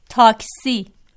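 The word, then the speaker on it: تاکسی: اور تلفظ جو ہے ٹیکسی کا کیا ہے؟ – ٹاکسی۔ جی ہاں، ٹیکسی۔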